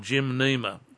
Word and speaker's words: gymnema: Now, the third thing in response to your question, Les, is there is a herb, an Asian herb, called gymnema. I'll spell it for you. G-Y-M-N-E-M-A.